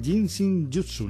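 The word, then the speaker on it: Jin Shin Jyutsu: Nos vas a hablar del Ginseng Jutsu. No lo he pronunciado bien. – Sí, lo has pronunciado bien.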